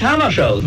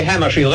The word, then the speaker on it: Hammarskjöld: Well, I pronounce it myself in Swedish Hammarskjöld, but if you say Hammarskjöld, that's all right with me.